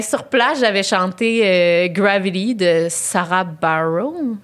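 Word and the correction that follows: Bareilles: Je sais pas comment on prononce ça. Barreille. – Sarah Bareil. Sarah Barreille, Bon, et elle, j'avais fait que j'avais chanté ça.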